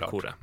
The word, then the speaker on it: Sakura: Nej, men om man kan de japanska uttalsreglerna så vet man att det blir såklart Sakura.